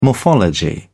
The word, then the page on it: morphology: British English